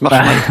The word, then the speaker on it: Marshall: Non, non, ça se prononce Marshall.